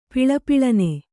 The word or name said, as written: ಪಳಪಳನೆ